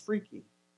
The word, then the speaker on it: FREKE: It's pronounced freaky.